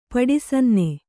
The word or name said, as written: ಪಡಿಸನ್ನೆ